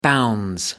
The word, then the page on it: bounds: British English